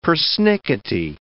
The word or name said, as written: persnickety